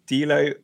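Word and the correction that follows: Dilano: Vantoff? – Delano. – Delano, sorry. Delano Vantoff. – We'll go with Delano. I'm 99% sure.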